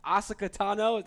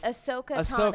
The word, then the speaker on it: Ahsoka Tano: Ahsoka Tano, that's the right way to pronounce it. – Oh, no, it's hard. It's Ahsoka Tano. – Ahsoka Tano, thank you. – That's okay. Everyone mispronounces it.